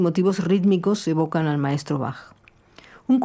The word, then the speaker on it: Bach: Esta baquiana, porque la che de Bach se pronuncia con sonido K, tiene la estructura de un aria da capo, ABA.